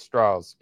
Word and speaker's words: Strauss: I go back to it's the, southern pronunciation. Straw. – Southern pronunciation. Strauss Yeah.